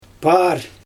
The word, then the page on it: paar: Xyzyl